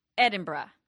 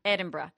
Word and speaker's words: Edinburgh: Is it Edinburgh? – Yeah. So hers, it's Edinburgh. – Mm-hmm. – Edinburgh. – That's the way I say it in my brain.